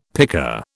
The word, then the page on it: pika: British English